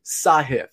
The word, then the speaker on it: Sahith: It is Sahith. And I don't mean to be the pronunciation police, but we keep saying his name. He's a rising star, in my opinion. We may as well get it right. It's Sahith, not Sahith. Sahith Thi Gala.